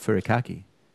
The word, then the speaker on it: furikake: I've only ever heard of it Furikake, K-A-K-E.